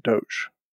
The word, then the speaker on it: Doge: That is how the thing got named, which means you have to say Doge. So when the founder is alive and they say very clearly on video that that's how you pronounce it, then that's how you pronounce it.